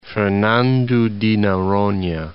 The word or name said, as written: Fernando de Noronha